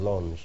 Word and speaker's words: Lens: Then we went from there to, I'm not sure, I think it was Lens, but the French pronounce it as Lens.